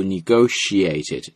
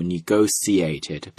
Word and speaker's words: negotiated: 注意谈判这个词呢，当然你可以读 negotiated，你可以读 negotiated， both are fine。 British English has the both pronunciations, 啊，有两个发音都可以 ,negotiated or negotiated, 都没有问题，它来自于 negotiate 这个单词，表示意思就是谈判 ,negotiation 是它的名字啊。